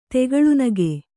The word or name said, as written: ತೆಗಳುನಗೆ